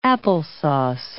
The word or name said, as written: applesauce